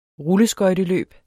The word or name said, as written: rulleskøjteløb